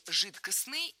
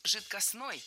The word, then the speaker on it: жидкостный: Да, итак, прозвучали варианты «жидкостный» и «жидкостной». А какой из них верный? Вариант «жидкостный» является литературной нормой. Ударение исходного существительного «жидкость» в нем сохраняется, но окончание должно быть «ый». «Жидкость» – «жидкостный».